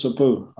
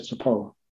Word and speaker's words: sabu: And what I said was not actually the accurate pronunciation of soap. Soap was sabu. I said sapo, which means pineapple.